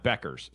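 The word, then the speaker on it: Bueckers: Beckers, sorry. Paige Beckers. I always get that wrong.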